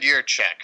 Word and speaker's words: Jiříček: David Jiříček. – Yep, it's David Jiříček.